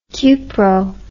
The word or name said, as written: cupro